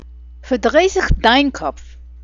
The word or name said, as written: fardraizich